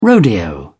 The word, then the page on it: rodeo: British English